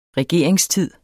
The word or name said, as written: regeringstid